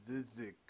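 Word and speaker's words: Žižić: Osman and Zizek. Zizek? I think that's how you say his name.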